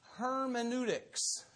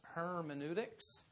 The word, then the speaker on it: hermeneutics: Biblical hermeneutics is how it is pronounced, hermeneutics.